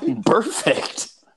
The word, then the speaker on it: Burfict: The one where Vontaze Burfict or Burfacet basically killed Antonio. – What? Burfict.